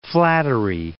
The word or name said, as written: Flattery, Cape